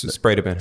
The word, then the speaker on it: Spredeman: It's Spredeman.